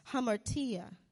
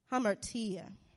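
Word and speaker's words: hamartia (ἁμαρτία): So, Greek word for sin is pronounced hamartia, hamartia.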